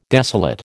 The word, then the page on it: desolate: American English